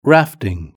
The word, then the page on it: rafting: British English